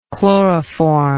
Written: chloroformic

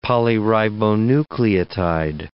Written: polyribonucleotide

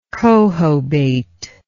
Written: cohobate

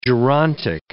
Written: gerontic

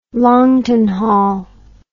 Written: Longton Hall

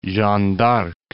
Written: Jeanne d'Arc

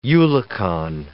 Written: eulachon

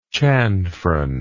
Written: chanfron

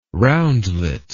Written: roundlet

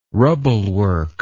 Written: rubblework

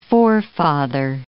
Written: forefather

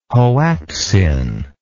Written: hoactzin

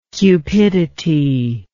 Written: cupidinous

